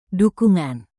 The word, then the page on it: dukungan: indonesian